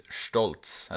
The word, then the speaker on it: Stolz: But yeah, we learned about, well, a bit about his past, and he has a big brother named Stolz. At least that's how it's pronounced in German.